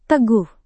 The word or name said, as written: teguh